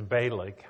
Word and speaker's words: Balak: Well, now that just set off Barak. He didn't like that at all. He was upset did I say Balak or Barak? Barak. I don't even remember. It's Balak. I may have been saying it wrong.